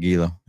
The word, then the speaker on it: Gila: I said Gila. That's right. That's how it's spelled.